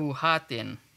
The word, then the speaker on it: buhatin: Oh, I'm going to assume the second syllable is emphasized. Mm-hmm. Buhatin. Is that correct? – Yep.